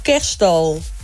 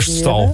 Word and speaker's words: kerststal: Is het nou cash cow, cash call of kerststal? Het kon is... ik er wel even wat beter uitspreken. – Kerststal. – Het is kerststal.